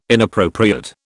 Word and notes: inappropriate: British English

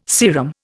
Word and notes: serum: Indian

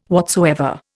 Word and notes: whatsoever: Indian

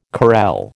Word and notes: carrel: American English

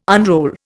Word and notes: unroll: Indian

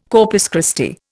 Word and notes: Corpus Christi: Indian

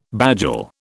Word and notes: bagel: British English

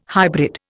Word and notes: hybrid: Indian

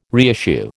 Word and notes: reissue: American English

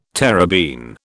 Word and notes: terebene: British English

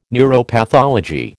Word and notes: neuropathology: American English

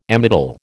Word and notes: Amytal: American English

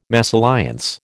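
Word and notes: mésalliance: American English

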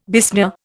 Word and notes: besmear: Indian

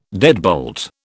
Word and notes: deadbolt: British English

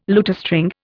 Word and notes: lutestring: Indian

